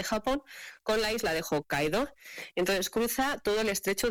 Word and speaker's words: Hokkaido: Igual que se desconoce otro perfil de islas que realmente están siendo emergentes y de hecho a la de Hokkaido, no sé si lo he pronunciado bien, perfecto, se cruza en túnel ferroviario submarino, que es el más profundo del mundo.